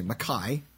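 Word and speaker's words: MacKay: In Leith, George McKay, McKay, whose surname rhymes with McFly,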